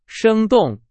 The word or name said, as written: 生动